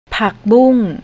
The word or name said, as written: pakboong